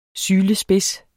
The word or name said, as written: sylespids